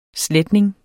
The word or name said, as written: sletning